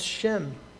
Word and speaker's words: Shem: The word name in Hebrew is pronounced Shem.